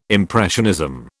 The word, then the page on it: impressionism: British English